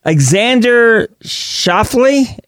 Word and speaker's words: Xander Schauffele: Speaking of golf, by the way, USA, Xander Schauffele.